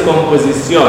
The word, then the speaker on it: composición: Cuando una persona dice esta palabra, ¿el estrés va en esta sílaba? No. – No. – ¿Va en qué sílaba? La pronunciación es composición.